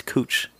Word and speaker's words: Couch: We were driving in Portland and there's a street, C-O-U-C-H. She was like, how do you say that? – Couch. – Yeah, it's cooch.